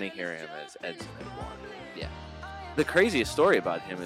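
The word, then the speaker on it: Édouard: Your Palace striker, who's going to make all of us rethink our forward strategy this week, is none other than Odson Edouard. Yeah, if you want to be real French Odson? About it, you don't really pronounce the final D in his last name.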